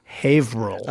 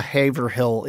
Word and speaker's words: Haverhill: It's like Haverhill Levine, like the musician. – Yeah, exactly. Yeah. So there's it's two syllables.